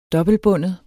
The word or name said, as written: dobbeltbundet